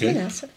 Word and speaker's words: Manasseh: Behold, my family is poor in Manasseh. Is that how you say it? – Mm-hmm. Yeah, okay. It's Manasseh.